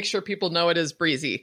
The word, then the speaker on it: Brizy: So it should be pronounced Brizy, not Brizzy.